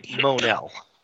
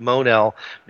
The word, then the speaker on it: Mon-El: Somebody asked how you pronounce that. And the editor responded that they pronounce it Mon-El because they moan at the number of times somebody writes in asking that question.